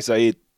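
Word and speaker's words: Saïd: And the other one is, related to the first bit of news, Saeed Taghmoui, who I've definitely mispronounced the name of, Saeed.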